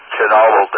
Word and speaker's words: Chernobyl: Chernobyl. – That's the name I was looking for. Let me let it beep. I sometimes hear it pronounced Chernobyl, but Chernobyl is the way we usually pronounce it.